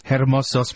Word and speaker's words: hermosos: Y ojos hermosos, pero veneno yo tengo. – That's why, because you see, you shouldn't pronounce the H.